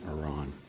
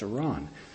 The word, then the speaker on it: Iran: They would look like maybe E-R-O-N, Iran. My inflection may not be perfect, but it's close to being correct. And I actually went online, and I just said, how do you pronounce Iran? And you get a little video, and you click it, and he was absolutely right, and I remembered absolutely right. It's Iran. Now, why say this?